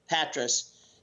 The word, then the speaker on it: Patras: Well, the next day we got up and we were sailing along, and we came up to Patras there. Is that how you pronounce it? – Mm-hmm. Yeah, that's how I say it. Yeah. – Yeah. Patras.